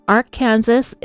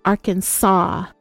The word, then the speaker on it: Arkansas: It's not Arkansas, it's not Arkansas, it must be pronounced Arkansas.